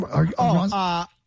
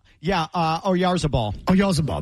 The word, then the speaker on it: Oyarzabal: Or Razabal, how you say his name? – Oyarzabal. – Oyarzabal, that's right.